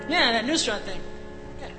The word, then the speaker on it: Nusrat: Because, no, because I have a friend named Penny, and she, I played her the thing, and she said, Nasrat. – Yeah. – It's Nusrat, and he's my Elvis.